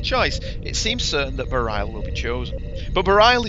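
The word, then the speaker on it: Bareil: Do I always say that name wrong? Is it Barail? – I think it's Barail.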